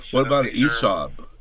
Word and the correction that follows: hyssop: Am I saying that right? The Bible had it as... – Hyssop. – Hyssop, yeah, that's what I'm trying to remember.